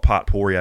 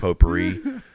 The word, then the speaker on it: potpourri: I woke up coughing to lavender smelly, I call it potpourri. I know it's pronounced potpourri.